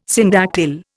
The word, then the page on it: syndactyl: Indian